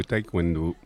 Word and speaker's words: taekwondo: Maintenant, on va parler du taekwondo, si je le prononce bien. C'est bien ça? – Oui, c'est ça, oui. C'est le taekwondo.